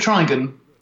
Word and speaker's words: Trigan: Do you say Trigan or Trigan? – Well, Don and I, when we talked about it, we called it Trigan.